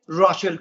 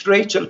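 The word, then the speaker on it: Rachel: راشل کوری یا با همون تلفظ آمریکایی ریچل کوری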